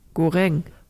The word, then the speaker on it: goreng: Het woord goreng, zoals je dat uitsprak, bestaat namelijk niet. De uitspraak moet zijn goreng, wat gebakken of gebraden betekent.